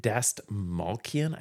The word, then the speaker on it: Dastmalchian: It's got David Dastmalchian, I think is how you say his name, who is someone who you will certainly recognize.